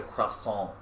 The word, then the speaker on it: croissant: Oh yeah, somebody saying they're ordering a croissant and they're like, it's a croissant, like the T is silent.